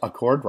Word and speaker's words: Accord: Spelled like Honda Accord, but pronounced Accord, right?